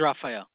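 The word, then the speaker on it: Rafael: Yeah, my name is Raphael.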